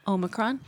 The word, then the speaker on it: Omicron: How'd you pronounce that again? – Omicron. – Just okay. – Suggest, so you're taking the English? – I'm going with English.